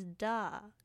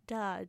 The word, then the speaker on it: Dha: Like, my last name is Da, Nitu Da, which is spelled D-H-A. Whenever I go to fill out a form or whatever, I always spell out my last name. I go, Nitu Da, D-H-A.